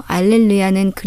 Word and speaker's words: 알렐루야: 할렐루야는 히브리어를 발음한 것이고 알렐루야는 그리스어를 발음한 것입니다. 그리스어에는 히읗 발음에 해당하는 문자가 없어서 그 소리에 가까운 이응 발음을 사용한다고 하네요.